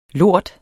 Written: lort

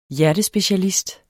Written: HJERTESPECIALIST

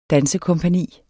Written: dansekompagni

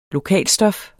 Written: lokalstof

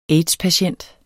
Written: aids-patient

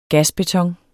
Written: Gasbeton

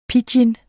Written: pidgin

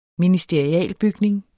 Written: ministerialbygning

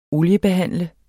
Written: oliebehandle

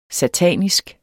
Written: SATANISK

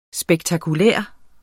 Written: spektakulær

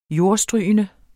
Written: jordstrygende

